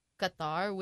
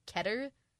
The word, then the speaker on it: Qatar: I'm pretty sure it's being held later this year, maybe in December, I think, in Qatar, which I actually have heard some people telling me it's not pronounced Qatar, it's pronounced Keter.